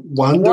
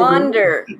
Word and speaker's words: Wander: how do I pronounce it? Is it Wonder Woman or Wonder? – Wander.